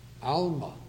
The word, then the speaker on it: almah: The first word is spelled B-E-T-H. U-L-A-H, Bethulah, Bethulah. The second word is spelled A-L-M-A-H, Alma, Alma.